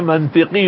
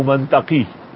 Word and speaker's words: منطق: اور کہتے تھے میں منطقی ہوں منطقی. تو میں نے بخاری کے ختم میں کہا کہ لفظ منطق ہے منطق نہیں ہے.